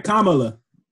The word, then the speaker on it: Kamala: Let's get her name right, Kamala.